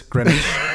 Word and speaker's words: Greenwich: Det udtales Greenwich.